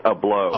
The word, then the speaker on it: Ablow: Well, you've got to pronounce his name right first. It's Keith Ablow.